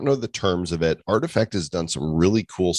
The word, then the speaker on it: RTFKT: RTFKT artifact is how you pronounce that as a collectibles startup, and they uh, they have been acquired by Nike.